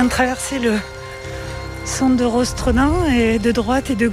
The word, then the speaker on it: Rostrenen: Alors, Rostrenen, il y a eu une grande polémique parce que Rostrenen, en breton, on prononce Rostren.